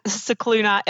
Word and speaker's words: Scicluna: Well, you can find me on Twitter at Scicluna Emily. So my last name is spelled S-C-I-C-L-U-N-A and then E-M-I-L-Y.